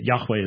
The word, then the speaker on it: Yahweh: But the people who don't have a vested interest in trying to get followers and just do scholarship have pretty much agreed with, I'd say, about 98% unanimity that Yahweh is the best the best pronunciation that is available.